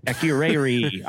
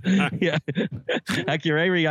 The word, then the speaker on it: Akureyri: I had this pronounced properly earlier. Akureyri, Iceland. Akureyri, Iceland.